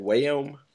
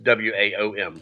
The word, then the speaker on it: WAOM: How do you say that hashtag? Wayom? Wow. – I just WAOM. – Okay, that makes more sense.